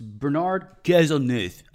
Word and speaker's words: Bernard Cazeneuve: The French Interior Minister, and you'll have to pardon me, it's Bernard Cazeneuve, I think is how the French would pronounce it. – But I think you said that correctly.